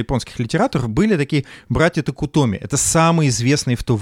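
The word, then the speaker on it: Токутоми (徳富): Токутоми, да. А, Соха, э, Сохо Токутоми и младший Рока Токутоми. – Как ты здорово это произносишь.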